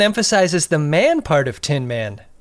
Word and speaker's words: Tin Man: I'm glad you pronounced it that way, because everyone else puts the emphasis on the man in this episode. Have you noticed that? When Picard does a log, he's like, "We're on our way to visit the Tin Man."